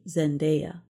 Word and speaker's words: Zendaya: The name of the actress Zendaya is often mispronounced as Zendaya, but it should be Zendaya.